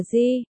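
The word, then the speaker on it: di (基): Từ cơ, di đọc là di, đồng âm với chữ g trong gay nên được dùng để chỉ gay.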